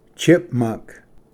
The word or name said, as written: ᎩᏳᎦ